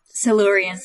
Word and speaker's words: Silurians: Silurians? How do you pronounce that? – Silurians.